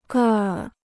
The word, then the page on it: 个儿: gèr